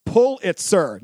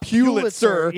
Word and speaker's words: Pulitzer: And Thornburg, the despicable journalist, mispronounces the most famous award in his profession, the Pulitzer, is pronounced Pulitzer, not Pulitzer.